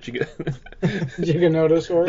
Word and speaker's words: Giganotosaurus: They seem to be coming to the Gigantosaurus. How do you say it? – Giganotosaurus? – That's the one, yeah.